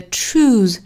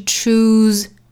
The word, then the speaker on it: choose: 这里面的 choose, 首先它后面的这个 se 读浊音的 z, 所以是 choose, choose. 注意里头这个 u 的发音从前面的辅音 ch 过渡到后面的口型 u, choose, choose, choose, choose dreams, choose dreams.